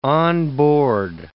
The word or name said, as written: onboard